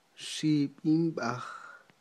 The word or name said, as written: xib'inb'aj